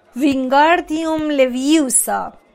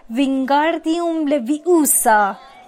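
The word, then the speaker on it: Wingardium Leviosa: دستای بلندش رو مثل آسیاب بادی تکون میداد و میگفت وینگاردیوم لویوسا. هری صدای هرمیونو شنید که با اوقات تلخی میگفت داری اشتباه تلفظ میکنی، باید بگی وینگاردیوم لویوسا، بهتر و کشیده تر باید تلفظ کنی.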